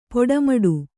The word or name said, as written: ಪಟ್ಟೆಮಡಿ